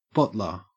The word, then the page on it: bottler: Australian English